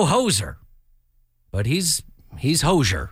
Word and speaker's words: Hozier: H-O-Z-I-E-R. Hozier, as I'm told it's pronounced. Not Hozier, not Hozier, but Hozier.